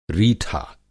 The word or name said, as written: रीठा